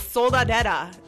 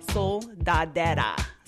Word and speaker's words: Soldadera: So it's Soldadera. That's my name. SOLDADERA. So @ Pronounce it one more time. – Soldadera.